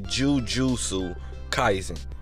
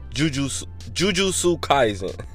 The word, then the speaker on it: Jujutsu Kaisen: I don't never know how to say this, G, because I think I've been pronouncing it Jujutsu, but it's not Jujutsu. It's actually Jujutsu Kaisen. Jujutsu Kaisen.